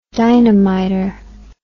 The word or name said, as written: dynamiter